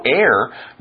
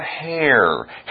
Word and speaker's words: heir: There's no heir, no one to take over. Notice the word heir is spelled with an H, but it's not hair.